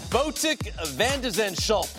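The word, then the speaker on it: Botic van de Zandschulp: If you don't know how to say it, it's Botik Vandeschulp.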